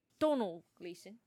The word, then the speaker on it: Domhnall: So I was like, Domhnall Gleeson. – Yeah, I guess what I was reading is like, I think the M is silent. So I think it's like, Donald Gleeson.